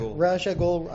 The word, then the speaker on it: Ra's al Ghul: See, what do you pronounce it? Ra's al Ghul. I've heard Ra's.